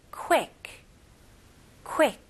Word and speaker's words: quick: 네, 여기서는 발음 조심하셔야 됩니다. 왜냐면 우리나라 말로도 quick이라고 하시잖아요. 근데 여기 모음 소리는 E 발음이 아니에요. E, I 발음으로 해주셔야 돼요. 그래서 quick보다는 quick, E. 에에 quick quick. 오케이? Okay? 그래서 너무 빠르게 짧게 하시는 것보다 모음 소리를 조금 길게 늘려 주시면 더 좋아요.